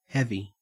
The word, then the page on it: heavy: Australian English